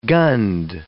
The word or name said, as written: gunned